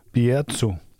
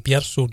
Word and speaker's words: Bierzo: Vi bliver i samme region, men rykker til området Bierzo. Udtales sådan? – Ja, jeg tror, altså nu, nu, nu taler jeg ikke sådan flydende spansk, men, men Bierzo, det er nok.